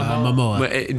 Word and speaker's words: Momoa: Momoa.